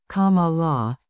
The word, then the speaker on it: Kamala: adults have a favorable opinion of Harris, whose name is pronounced Kamala, while about half have an unfavorable opinion.